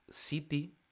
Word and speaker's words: ziti: El siguiente que tenemos, el siguiente tipo que tenemos se llama Baked City, que no es una ciudad, se escribe Z-I-T-I.